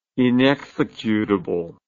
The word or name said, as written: inexecutable